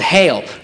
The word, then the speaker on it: Geel: There's a small little village in Belgium called Heel, right? It's spelled G-E-E-L because they're Belgium and don't give a shit about American letters.